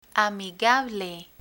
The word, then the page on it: amigable: Spanish